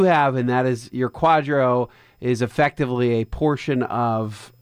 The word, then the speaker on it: QDRO: Q-D-R-O, pronounced Quadro, and it's basically a judicial order in the U.S.